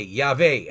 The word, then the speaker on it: Yahweh: Yud-Heh-Vav-Heh, Yahweh, as I pronounce it.